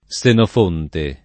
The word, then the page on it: Senofonte: lingua italiana